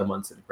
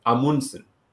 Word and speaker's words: Amundsen: So apparently like the right way to say it is Amundsen. And the first way we started saying this was Amundsen. So it's a very like plain, mellow way. And now, And now that's changed to Amundsen is like another common way, which is how people refer to. That's also, I have changed my pronunciation to that way now. I say Amundsen, which is still not the Norwegian.